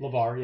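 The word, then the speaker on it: LaVar: LeVar, yeah.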